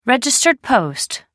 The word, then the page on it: registered post: American English